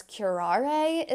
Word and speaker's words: curare: And so there's one poison known as curare, is how you say it. It's C-U-R-A-R-E.